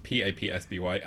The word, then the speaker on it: Papsby: You can find me on Twitter, Letterboxd, TikTok, Hinge, OnlyFans, at PAPSBY, at PAPSBY. That's at Papsby. It rhymes with Don's Plum.